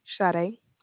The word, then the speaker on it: Sade: Sade. It's spelled S-A-D-E. – S-A-D-E? – Yes, ma'am.